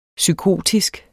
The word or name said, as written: psykotisk